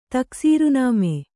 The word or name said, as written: ತಕ್ಸೀರುನಾಮೆ